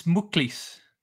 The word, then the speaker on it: Mwclis: MWCLIS. Midikleish. – It's Muklis. – Muklis. Ah, man. Did not expect a U sound in there. – All good. Yeah, Muklis means necklace.